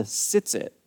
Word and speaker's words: tzitzit: Now, this little piece of fabric is called the tzitzit. The tzitzit, it's hard to say in Hebrew, but I would spell it T-Z-I-T-Z-I-T, tzitzit.